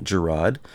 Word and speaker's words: Girod: Now, a federal judge or a federal court determined that the Amish farmer, his name was Samuel Girod, I think I'm pronouncing that correctly, G-I-R-O-D, Girod, mislabeled his herbal home remedies, which were for sinus infections, skin disorders, and cancer.